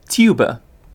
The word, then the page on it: tuba: British English